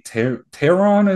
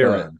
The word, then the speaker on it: Teheran: Julio, I think Teheran is how you pronounce it. – Okay, Teheran, got it.